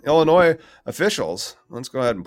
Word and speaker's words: Illinois: If you want to actually be in Illinois, you don't pronounce the S. But if you call it Illinois, we just think you're not from there.